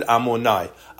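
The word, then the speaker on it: Amonai (אדני): So we read Hashem's name in the Situr, a yud, a hey, a vav, and a hey. That's 26, but we pronounce it Amonai.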